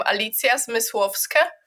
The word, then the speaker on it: Alicja Zmysłowska: So, yes, I'm Alicja Zmysłowska.